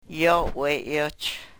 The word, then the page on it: yo we-yoch: Yurok